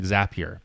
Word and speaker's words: Zapier: And just so you know, I like the fact that you said it correctly, Zapier, because if people say Zapier, it's Zapier because Zapier makes you happier.